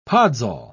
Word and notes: podzol: British English

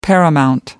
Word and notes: paramount: American English

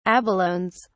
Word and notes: abalones: British English

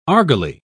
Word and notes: argali: British English

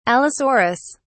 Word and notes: allosaurus: British English

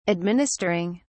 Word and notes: administering: British English

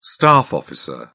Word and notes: staff officer: British English

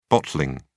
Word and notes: bottling: British English

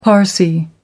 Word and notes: parsi: American English

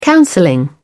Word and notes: counselling: British English

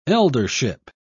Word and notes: eldership: British English

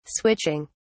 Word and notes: switching: British English

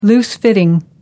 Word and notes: loose-fitting: American English

